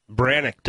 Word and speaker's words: Brannock: And they have right foot, left foot, where you put Brannick device. – Yes, Brannick, that's it, the Brannick. How do you spell it? – B-R-A-N-N-O-C-K. – Brannick, the Brannick device.